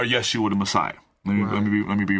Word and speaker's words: Yeshua: But either way, the correct pronunciation is Yeshua.